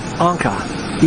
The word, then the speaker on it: Encke: and the manufacturer's name is Anka, E-N-C-K-E. That's what it says on top of this little spool. So she told me how to pronounce it.